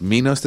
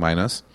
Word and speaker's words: Minus the Saint: And now it's Minos the Saint, but it's spelled I-N-O-S, because I was saying minus. But Minos, my... I'm still saying it wrong. The Saint. – Yeah. – Minus, but it's spelled M-I-N-O-S. I'm confusing myself. Minus the Saint.